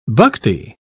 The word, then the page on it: bhakti: British English